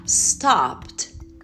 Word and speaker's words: stopped: Então você vai pronunciar stopped, stopped, put, put. Você vai pronunciar o p, p, que é o P, e o t, t que é o T. Pt, alright? No final.